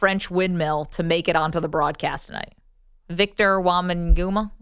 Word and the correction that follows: Wembanyama: I'm going to butcher this guy's name. He's the next Giannis. Victor Wembanyama.